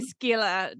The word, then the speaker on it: Scylla: Oh, I, I say skila. No, yeah. Oh, I Good. know where the I mean I'm not great at Greek I will say but I definitely know there's no silent k or c in there.